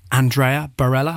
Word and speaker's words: Andrea Barrela: I am joined this evening by Gavin Love and Andrea Barrela.